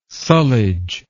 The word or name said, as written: sullage